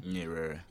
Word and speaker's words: Nyerere: Nyerere.